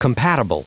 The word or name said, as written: compatible